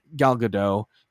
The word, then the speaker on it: Gal Gadot: There's going to be a Snow White live action remake, which is going to star Rachel Zegler and Gal Gadot, that's how she pronounces it.